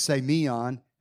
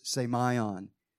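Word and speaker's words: say my on (σημεῖον): Last week I, I said say me on, and I went and double checked the pronunciation, and it is say my on.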